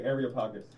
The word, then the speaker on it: Areopagus: Areopagus. Help me out. How do you say that word, man? – I say Areopagus.